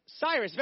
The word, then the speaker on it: Cyrus: Begins with a C. And then a Y. Rhymes with Iris. Cyrus, very good.